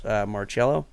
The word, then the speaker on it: Marcello: But he also made friends with Carlos Marcello. Fact check me, pronunciation. – Yep.